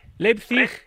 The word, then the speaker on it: Leipzig: Leipzig, sí, lo he dicho bien, hombre, por favor, por Dios.